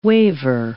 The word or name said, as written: waiver